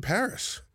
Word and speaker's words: Parris: We got Colin Parris. I pronounced that last name right. – Yes, you did. – Just Parris. – That's right. Like the city.